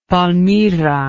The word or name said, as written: palmira